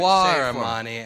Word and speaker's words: Ghahremani: Ghahremani, as if, you know, the artsy alien penis band, Guar.